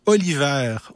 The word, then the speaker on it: Oliver: Puis là, je suis tombé sur l'artiste Nell, ça s'écrit N-E-L, espace Oliver, O-L-I-V-E-R, donc mon, prénom, Nell Oliver, qui maintenant habite au Bénin.